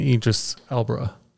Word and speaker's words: Idris Elba: They were actually between Will Smith and Idris Elba.